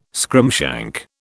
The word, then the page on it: scrimshank: British English